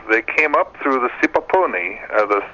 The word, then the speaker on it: Sipapuni: He says, I would appreciate if you can ask your guest about, I'll try to pronounce this correctly, Sipapuni. S-I-P-A-P-U-N-I. Sipapuni.